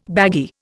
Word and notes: baggy: Indian

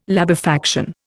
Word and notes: labefaction: Indian

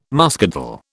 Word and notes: muscadel: British English

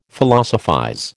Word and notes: Philosophize: American English

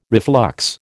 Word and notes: reflux: American English

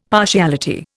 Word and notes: partiality: Indian

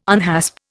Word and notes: unhasp: Indian

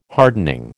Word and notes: hardening: American English

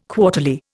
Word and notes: quarterly: Indian

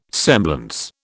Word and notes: semblance: British English

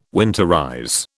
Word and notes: winterize: British English

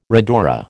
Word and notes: rhodora: American English

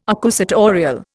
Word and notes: accusatorial: Indian

